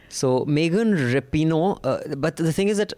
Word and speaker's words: Rapinoe: In case you're wondering who we're talking about, we're talking about Rapinoe. I think that's how you pronounce her name.